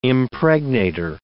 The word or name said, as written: impregnator